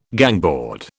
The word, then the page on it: gangboard: British English